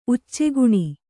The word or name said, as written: ಉಚ್ಚೆಗುಣಿ